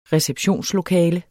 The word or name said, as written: receptionslokale